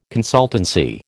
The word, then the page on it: consultancy: American English